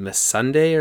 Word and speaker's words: Missandei: So it starts off, we have Grey Worm and Missandei, or how do you pronounce her name? – Yeah, that was pretty good.